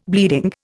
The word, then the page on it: bleeding: Indian